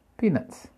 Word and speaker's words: peanuts: Peanuts, peanuts, peanuts. P-E-A-N-U-T, peanuts. Acuérdate de pronunciar la T al final, no se lo olvide.